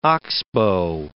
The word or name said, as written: oxbow